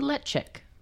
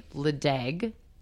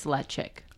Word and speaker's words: Ledaig: When you say lechick, how do you spell that? – L-E-D-A-I-G. – L-E-D-A-I-D. – Lechick. So if you want to say ledeg, don't. – Okay. – It's lechick.